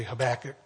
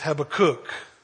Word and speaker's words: Habakkuk: Habakkuk. That's the correct way. I always say Habakkuk, but that's white boy American. The right way to say it is Habakkuk. Like, think of a kook. Do you have a kook? Yeah, I got a kook. Habakkuk, that's how you say it.